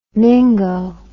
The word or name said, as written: mingo